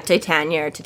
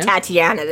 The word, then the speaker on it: Titania: So like Summer Court, like the queen, Titania? How do you say her? – Yeah, Titania, but it's just not Tatiana that she keeps trying to switch to.